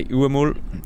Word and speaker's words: Huemul: The Uemul, which is spelled with the H, with the silent H, it's like a Huemul. is the Umul is an animal.